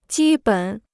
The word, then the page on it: 基本: jī běn